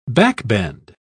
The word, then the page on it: backbend: British English